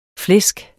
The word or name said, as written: flæsk